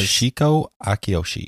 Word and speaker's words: Toshiko Akiyoshi: Because, Toshiko Akiyoshi.